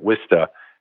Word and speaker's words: Worcester: And, uh, yeah, pronounce, you know, to the rest of the country, Worcester, but Wista as they pronounce it in the Boston area.